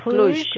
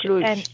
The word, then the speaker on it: Cluj: C-L-U and John. J like G- in John. – Okay. – John. Cluj. Cluj.